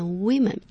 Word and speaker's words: women: 女人一个，但是复数它的发音不一样的，念 women，